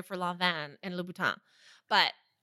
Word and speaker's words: Lanvin: It's not Lanvin, it's Lanvin.